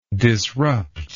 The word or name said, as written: disruptor